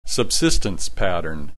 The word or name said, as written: subsistence pattern